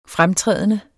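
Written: Fremtrædende

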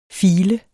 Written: file

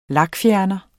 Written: lakfjerner

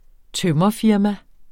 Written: tømrerfirma

